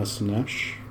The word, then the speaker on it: Szenes: So this week I'm doing Hannah Snesch. Have you heard of her? – No. – It's spelled Szenes. It's Hebrew and Hungarian.